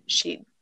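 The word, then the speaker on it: sídhe: myself on this one because before I even looked up this one, it's spelled S I D H E. I automatically said Sheed.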